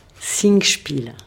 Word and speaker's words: Singspiel: Maggie Marin, vous nous parleriez de Spingle, je le prononce pas mal. – Singspiel. – Singspiel. – Voilà.